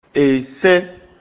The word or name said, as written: Esɛ́n